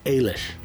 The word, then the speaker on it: Eilish: Finally, I am pronouncing her name correctly. I know this because she told me how it was pronounced. She describes it as an Irish form of Alice. Eilish.